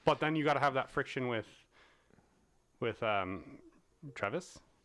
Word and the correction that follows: Treves: Is it Travis? – Treves.